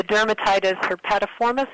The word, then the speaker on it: dermatitis herpetiformis: The dermatitis herpetiformis, is that how right.